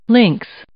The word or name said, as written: links